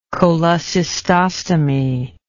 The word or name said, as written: cholecystostomy